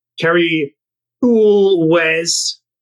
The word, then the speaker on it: Cary Elwes: but Kerry Ulwes, if that's how you say it, Josh. – Yeah, you definitely say it with that very exaggerated.